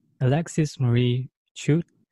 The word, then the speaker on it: Alexis Marie Chute: Alexis Marie Chute is here with us, and I hope that I pronounced that correctly.